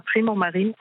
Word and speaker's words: Primo Marine: Ik weet eigenlijk niet of ik het Nederlands of Engels moet uitspreken. Goedemiddag overigens. – Ja, goedemiddag. Het is Nederland, het is Primo Marine. – Ah, Prima, Primo, mooi zo.